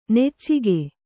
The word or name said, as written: ನುಚ್ಚಕ್ಕಿ